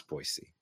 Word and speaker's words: Boise: That is correct pronunciation, by the way. It's Boise.